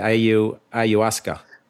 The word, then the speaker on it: ayahuasca: The ayahuasca.